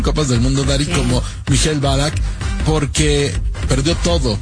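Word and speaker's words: Michael Ballack: ¿Cómo se dice bien? ¿Mikhail Balak? – Mikhail Balak, sí.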